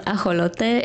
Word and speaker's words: ajolote: So an axolotl, it's pronounced ajolote in Spanish.